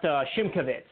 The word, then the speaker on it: Szymkowicz: No, sir, you didn't. But no slack on you. It took me years to finally learn how to say that correctly. It's pronounced Szymkowicz. It's very, very Polish.